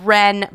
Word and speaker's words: Wren: The next place right down the street from that, which we went to immediately after we went to Hilo Basin, is called Wren Brewing House. And Wren is spelled W-R-E-N, like the bird.